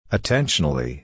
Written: attentionally

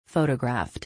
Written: photographed

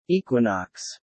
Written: equinox

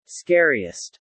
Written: scariest

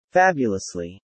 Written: fabulously

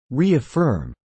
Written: reaffirm

